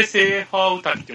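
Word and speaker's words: 斎場御嶽: あ、これでセーファーウタキって読むんですか。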